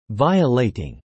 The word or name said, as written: violating